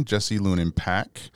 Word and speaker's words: Lunin-Pack: His name is Jesse Lunin-Pack of Lunin-Pack Group, Financial Group.